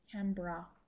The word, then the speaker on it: Canberra: Canberra, is that what it is? – Canberra.